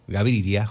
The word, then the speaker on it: Gaviria: Eduardo nos está escuchando y nos está pidiendo la música de José Gari, Gaviria, Gaviria, lo digo bien, Gaviria, José Gaviria y Jorge Villamizar.